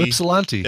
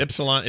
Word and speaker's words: Ypsilanti: We got one from Adam from Yesplanty, Michigan, or how do you say it? YP. – Oh, Ypsilanti. – Ypsilanti. Is that how you say it? Okay. Wow, you are Ypsilanti. not even close.